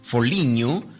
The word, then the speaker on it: Foligno: Y en el Ángela de Foligno o también como se pronuncia en italiano, Foligno,